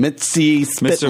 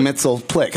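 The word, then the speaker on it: Mxyzptlk: Gilbert Gottfried said it's Mix Yeez Spit Lick. Okay. And that's the pronunciation. I like that pronunciation. Mitzi Spitlick. – Mr. Mitzelplitlick. – But I grew up saying Mitzelplick.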